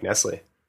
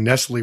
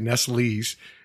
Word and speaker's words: Nestlé: And then I, you know, and I even found out I was pronouncing Nestle wrong. I was pronouncing it Nestle's.